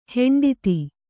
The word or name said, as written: ಹಿಂಡೆತ್ತು